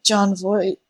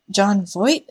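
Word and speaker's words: Jon Voight: People pointed out that he looks like John Voight. John Voight? Is that how you say his name? – That is how you say his name.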